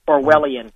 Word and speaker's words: Orwellian: Orwellian.